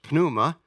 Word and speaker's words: pneuma: It's pneumonia, and it's not, it's pneuma, or pneuma in Greek, it actually pronounces the pi on there, it's pneuma, and the word is associated with pneumonia.